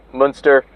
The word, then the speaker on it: Münster: You can look up Münster. It's spelled like monster, but with a U, and I think it's EU. Münster, like Herman Münster.